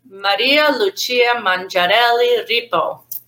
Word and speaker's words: Maria Lucia Mangiarelli Rippo: So my maiden name, including my middle name with my married name, is Maria Lucia Mangiarelli Rippo. I say it like this because it's Italian.